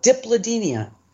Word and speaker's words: Dipladenia: D-I-P... L-A-D-E-N-I-A. – Okay, so you started with, Diplodenia, D-I-P. Got it. – That's it. Just the way it's spelled, Diplodenia.